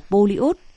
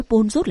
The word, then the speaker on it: Polyot: Tao rốt ngoại ngữ, nhưng cũng được người ta dạy rằng phải phát âm là Polyot, chứ nói pôn rốt là sai.